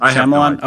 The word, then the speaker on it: Shyamalan: Well, I feel like in order for Shyamalan – how is it pronounced? Is it Shyamalan? Is that right? – Shyamalan. – Shyamalan?